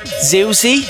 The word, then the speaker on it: Ziwzih: Ziwzih Ziwzih. That's, I believe, how it's pronounced.